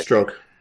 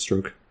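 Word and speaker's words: Strzok: Or Sturzok, or however you pronounce that guy. – Stroke. – Yeah. – It's Stroke.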